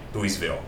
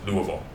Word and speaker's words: Louisville: 但是，一般如果你去那边念，你不能念 Louisville，是 Louisville。所以其实是一个很特别的地名。大家大家可能平常拿那个球棒不知道怎么念，但事实上它是念 Louisville Slugger，而不是念 Louisville Slugger。虽然它中文翻译成路易斯威尔，但是它其实是是没有 S 的。嗯。那这个这个小镇其实蛮特别，它虽然它并不大，它就是一个很小的城镇。